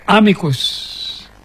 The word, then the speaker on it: amicus: Yun daw po Latin words, the accent is always on the first syllable. So we say amicus.